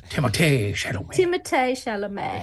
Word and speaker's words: Timothée Chalamet: No, you're pronouncing it wrong. Timothée Chalamet.